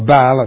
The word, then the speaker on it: Baal: Now, of course, the word Baal, or Baal as some pronounce it, appears 6 times in the translation of the NIV in Hosea.